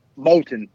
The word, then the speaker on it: Moulton: It's Moulton.